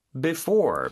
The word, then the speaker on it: before: 자, 뭐뭐 하기 전에라는 단어가 바로 Before, B-E-F-O-R-E. – 맞아요. 그리고 강세가 이음절에 있다고 했었죠. 그래서 before 이렇게 발음이 됩니다.